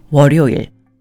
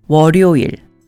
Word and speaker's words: 월요일: 월 followed by 요일, 월, is pronounced 월요일, which is Monday. 월요일